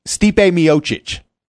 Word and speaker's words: Stipe Miocic: Oh, Stipe Miocic, heavyweight champion of the UFC. He's in Cleveland. And say that name again. – And Stipe Miocic. That's how you say his name.